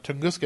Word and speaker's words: Tunguska: Well, one of the questions that came up in the chat room from our friend Chops was about the Tunguska incident.